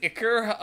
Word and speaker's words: ichor: And is that how it's pronounced? Ichor or an ichor? – I think ichor is the correct pronunciation.